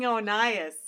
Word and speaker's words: Oeneus: No, King Onias. – Spell it. – O-E-N-E-U-S. Onias.